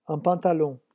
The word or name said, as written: un pantalon